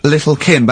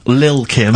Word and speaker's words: Lil Kim: Now, I see there it's a track by Little Kim, but how are you pronouncing it? – I'm pronouncing that correctly. Lil Kim. – Oh, no T's involved.